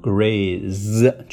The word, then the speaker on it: graze: Graze，注意这里 z 就发成 z 的音。